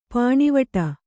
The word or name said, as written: ಪಾಣಿವಟ್ಟು